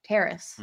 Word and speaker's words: Terrace: Like, well, and then hopefully they put in Terrence Marshall. Terrace. Mm. – Terrace, yeah. – I can't remember. Terrace. We haven't talked about him so long. I knew I was probably saying it wrong, but uh yeah, maybe they'll put in Terrace.